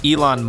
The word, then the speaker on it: Elon: Like, it kept pronouncing Elon Musk as Ellen Musk. So I had to go in and spell it out, E-lon Musk.